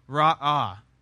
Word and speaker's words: ra'ah: The first word is ra'ah. It's two syllables.